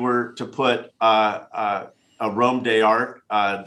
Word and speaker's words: Arôme d'Art: but it's it's uh, the website is a Rome Dart a r o m e d a r t dot com, mm-hmm.